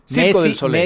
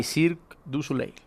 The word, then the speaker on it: Cirque du Soleil: A ver, Circo del Soleil. Messi 10 by Cirque du Soleil.